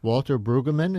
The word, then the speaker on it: Brueggemann: Walter Brueggemann.